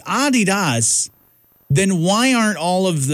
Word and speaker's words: Adidas: Or Americans, this fun fact is that Americans pronounce Adidas wrong. It's actually supposed to be Adidas with a slight emphasis on the first syllable. So say it with me, Adidas. There you go. Not Adidas, like we all pretty much say.